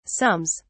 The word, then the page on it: sums: British English